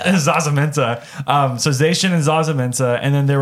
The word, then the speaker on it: Zacian: It's not Zacian. Well, how do you pronounce it? – Zacian is right.